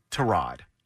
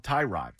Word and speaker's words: Tyrod: Today, officially, from the Cleveland Browns, the way that his name is to be pronounced is Tyrod. – That's crazy. – He's been on this planet 29 years, and he's forever been called Tyrod.